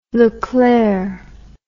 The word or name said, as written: leclerc